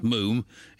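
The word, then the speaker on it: Múm: but it's got an accent over the U, so it's pronounced Moom, in the same way the pulp is pronounced Poolp.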